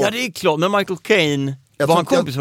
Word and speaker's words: Michael Caine: Det är som att säga My Cocaine. Alltså, Michael Caine. – Michael Caine. – Ja, för han pratar ju Cockney.